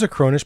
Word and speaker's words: Acronis: And it's spelled A-C-R-O-N-I-S, Acronis.